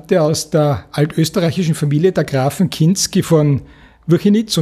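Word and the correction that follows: Wchinitz: und Tettau, wenn ich das richtig ausgesprochen habe. – Kinitz und Tettau, ja.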